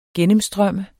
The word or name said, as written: gennemstrømme